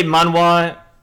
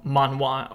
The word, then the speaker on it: manhwa: So if you hear us say manhwa, it's not that we're not pronouncing manga, it's because it's Korean, so it's actually called manhwa, or however the pronunciation is.